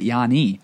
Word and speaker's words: Giannis: Where he's from in Greece, they don't pronounce the S, and so they pronounce it Yanni.